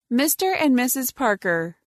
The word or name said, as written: Mr and Mrs Parker